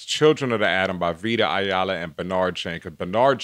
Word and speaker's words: Vita Ayala: Vita Ayala, yes.